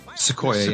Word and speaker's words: Sequoia: Is that how you say it, though? – Sequoia, yeah. – Thank you. Thank you.